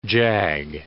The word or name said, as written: jag